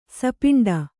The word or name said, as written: ಸಪಿಂಡ